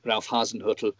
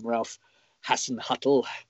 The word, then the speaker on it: Ralf Hasenhüttl: Congratulations, by the way, on being able to pronounce his name way better than 90% of people that work for the English Premier League. A lot of my colleagues so insist on calling him Ralf Hasenhüttl, which drives me, of course, as a German speaker, absolutely bonkers.